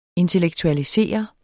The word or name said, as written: intellektualisere